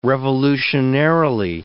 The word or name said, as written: revolutionarily